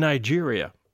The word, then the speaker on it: Niger: France got the area that we call Niger. – Okay. – That's why it's pronounced that way, like a French word.